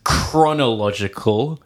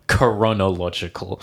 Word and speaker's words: chronological: It's chronological, not coronological.